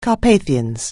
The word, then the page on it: Carpathians: anglais